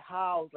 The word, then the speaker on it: Howze: Well, I pronounce it Howze, like how's it going, but I'm in the house, so it all works out.